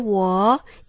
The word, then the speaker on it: wall: I misjudged the height of the wall and hurt my ankle. – I misjudged the height of the wall and hurt my ankle. – 好，注意到你那个 war 不能念成 war， war 有点像战争了。 – 嗯，哦，它是 L 的音，所以念 war。